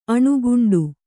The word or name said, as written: ಅಣುಗುಂಡು